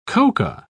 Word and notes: coca: British English

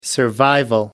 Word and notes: survival: American English

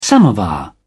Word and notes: samovar: British English

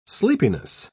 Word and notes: sleepiness: British English

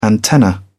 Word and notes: antenna: British English

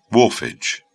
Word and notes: wharfage: British English

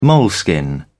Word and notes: moleskin: British English

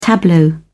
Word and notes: tableau: British English

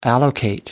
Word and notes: allocate: American English

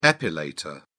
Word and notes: epilator: British English

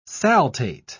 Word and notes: saltate: British English

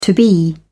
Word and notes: to be: British English

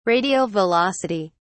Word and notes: radial velocity: British English

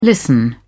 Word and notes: listen: British English

